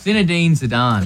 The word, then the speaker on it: Zinedine Zidane: It's Zinedine Zidane.